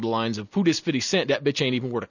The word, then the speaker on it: 50 Cent: When 50 Cent, I should say this correctly, he's not 50 Cents, he is 50 Cent.